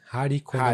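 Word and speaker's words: Hari Kondabolu: Um é do Harry Condab... Condabolo Warn Your Relatives. Cê, falei certo? Condabolo? – Eu acho que é Hari, né? Hari Condabolo. Hari Condabolo.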